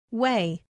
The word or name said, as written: way